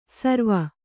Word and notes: ಸರ್ವ: sarva

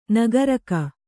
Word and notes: ನಾಗರೀಕ: Kannada

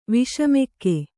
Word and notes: ವಿಷಮಗೋ: kannada

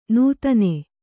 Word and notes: ನೂತನೆ: nūtane

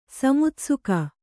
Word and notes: ಸಮುತ್ಸುಕ: samutsuka

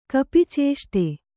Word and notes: ಕಪಿಚೇಷ್ಟೆ: kannada

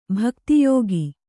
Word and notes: ಭಕ್ತಿಯೋಗಿ: kannada

